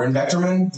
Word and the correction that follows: ivermectin: Is that how you say it? – Ivermectin.